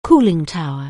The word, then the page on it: cooling tower: anglais